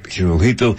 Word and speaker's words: Hirohito: By the way, the Emperor of Japan, his last name is spelled a couple, H-I-R-O-H-I-T-O. Most people pronounce it Hirohito. It's actually the correct pronunciation is Hirohito.